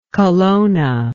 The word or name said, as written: colonna